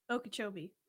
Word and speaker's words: Okeechobee: Okokajobi-dobi. What's the name of that lake? – Okeechobee. – I said it right the first time.